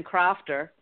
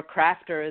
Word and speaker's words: Crafter: Well, you know, it's funny because you know, with the last name Crafter, or Crafter as we pronounce it over in the States, it was just a natural.